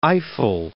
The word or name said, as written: Eifel